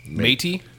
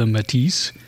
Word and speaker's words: Métis: Métis? Like marshmallow Métis? – It's spelled like Métis, but sometimes the S is silent depending on who's doing it.